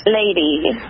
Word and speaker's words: Leiy: Lady. – Lady. – L-E-I-Y, okay.